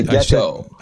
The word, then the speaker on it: gecko: Yeah, but I'm more shocked you're pronouncing it as Geico like the insurance company. – I said, it's a gecko.